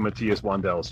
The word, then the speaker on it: Matthias Wandel: the other thing I did want to mention is, yes, I have been watching Matthias Wandel's channel, and is that how you pronounce it? – Yeah, I guess Matthias's channel.